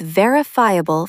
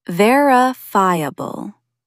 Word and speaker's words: verifiable: When an essay makes a bold claim, back it up with verifiable facts. Stress the first syllable. Verifiable.